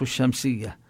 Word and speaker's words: الشمس: أما الحروف التي تضغم فيها هذه اللام يقال لها الحروف الشمسية، يعني كما نقول الشمس والشمس وضحاها، فهنا لا تلفظ اللام.